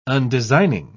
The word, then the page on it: undesigning: British English